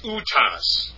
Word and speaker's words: autos: Actually, it's autos. Pardon me, I mispronounced that.